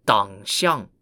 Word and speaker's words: Dangxiang: Rather, the Xi Xia was a kingdom ruled by an ethnic group called the Tanguts, or pronounced in Chinese as Dangxiang, spelt D-A-N-G-X-I-A-N-G.